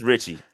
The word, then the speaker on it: Ricci: Um, but if you're gonna if they like Sullivan so much and Rishi Rishi so much, and I'm butchering his name, and I, I apologize. It's Ricci. – I, I butchered Ricci. it too. It's Ricci.